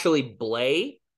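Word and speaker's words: Blais: It's a French name, and I'm always bad at that. – Blay. Like, it's probably just pronounced Blay, and we all say it wrong.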